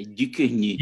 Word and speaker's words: Ducournau: Filme é, novo da Julia Ducournau. Tá vendo? Essa, esse, esse nome eu pronuncio da forma correta. É Ducournau. E da, da mesma diretora de Raw.